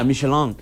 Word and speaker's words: Michelin: Michelin.